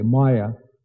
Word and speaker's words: maya: So the maya, actually, the word is, it's a short a maya.